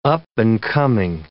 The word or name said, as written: up-and-coming